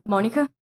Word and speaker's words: Monika: Monica. – How do you spell it? – M-O-N-I-K-A.